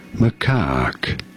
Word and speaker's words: macaque: Macaque. – What? – You do want to stress on the second syllable, on the cack syllable.